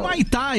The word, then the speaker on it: Humaitá: Humaitá, isso mesmo, falei certo.